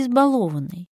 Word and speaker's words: избалованный: Избалованный или избалованный? Балуешь или балуешь? Нет-нет, я не заставлю вас путаться в ударениях. Запоминаем правильный ответ. Избаловать, балует, избалованный. Чтобы привыкнуть к правильному ударению, нужно стараться чаще употреблять сложное слово, всякий раз сверяя себя с нормативным произношением.